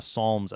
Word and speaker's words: Psalms: any sort of introductory material on the Psalms as a whole. – First, remember the P is silent.